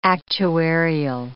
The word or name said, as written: actuarial